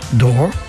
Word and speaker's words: door: Ou alors, il en reste 2 autres, qui se prononcent comme more ou store, ce sont la porte, door, et le sol, floor.